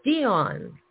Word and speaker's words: Dion: I'm saying your name wrong with the wrong accent. Dion. That's right.